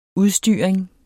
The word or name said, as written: udstyring